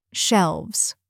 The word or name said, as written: shelves